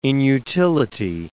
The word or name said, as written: inutility